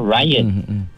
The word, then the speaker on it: rayan: Rayan ni ejaan dia R-A-Y-A-N. Rayan. Ni macam nama orang pun ada juga no. Ryan. Ryan. Ejaan dia jadi Rayan lah. Tapi sebutan dia Rayan.